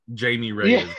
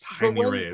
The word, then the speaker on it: Jaime Reyes: I think Ted Kord has a bit of a part in this movie, but they're going with Jaime Reyes or as the white people would say Jaime Reyes, but that's not how you say his name. It's Jaime Reyes.